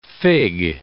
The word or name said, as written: fig